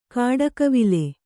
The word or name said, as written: ಗಿಡಗಾವಲು